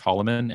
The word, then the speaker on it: Hollomon: So you can find me at Luke Hollomon. And to spell my name, I grew up next to an Air Force base. It's spelled very slightly differently, so I've gotten sensitive about it, but it's H-O-L-L-O-M-O-N. That's Luke Hollomon on Twitter. – Right, not M-A-N.